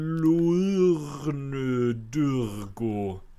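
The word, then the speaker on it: Luornu Durgo: Jag kan dock säga att Duo Damsel, alltså Triplicate Girl, som på svenska, eller på svenska, på skånska ska man uttala hennes namn, för hon heter Lurnu Durgo.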